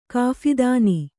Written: ಕಾಫಿದಾನಿ

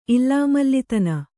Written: ಇಲ್ಲಾಮಲ್ಲಿತನ

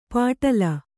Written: ಪಾಟಲ

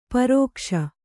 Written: ಪರಿಘೋಷ

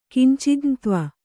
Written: ಕಿಂಚಿಜ್ಞತ್ವ

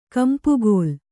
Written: ಕೆಂಪುಕಲ್ಲು

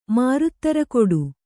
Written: ಮಾರುತ್ತರಕೊಡು

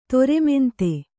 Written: ದರ್ಮಾಂಧ

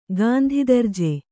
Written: ಗಾಂಧಿದರ್ಜೆ

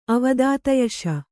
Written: ಅವದಾತಯಶ

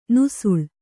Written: ನುಸುಳು

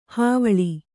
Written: ಹೈವಳ

